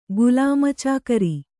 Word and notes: ಗುಲಾಮಚಾಕರಿ: Kannada

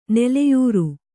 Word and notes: ನೆಲೆಯರಿ: neleyri

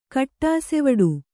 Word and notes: ಕಟ್ಟಾಸೆವಡು: Kannada

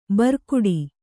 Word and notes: ಭ್ರುಕುಟಿ: kannada

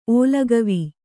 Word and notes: ಓಲಗವೀ: ōlagavī

